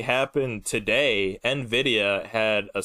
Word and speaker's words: Nvidia: Nvidia, did I say that right? – Nvidia.